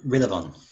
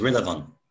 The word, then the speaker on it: Rilavon: Oh, it is Rillavon. – Rillavon, interesting. – Right, would you mind saying that again? – Yes, it is spelled how it sounds. It is Rillavon. – Rillavon. – R-I-L. A-V-O-N.